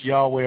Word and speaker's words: Yahweh: And I know for those of you who understand Hebrew out there, you know, you wonder why I say Adonai instead of the tetragrammaton Yad Heh Vav Heh, you know, which is pronounced Yahweh or whatever, because I do that out of respect to my Jewish brethren.